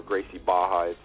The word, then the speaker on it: Gracie Barra: We're talking about Gracie Barra, or Gracie Baja. It's Portuguese. All the R's are silent. That's why they say pronounce Baja.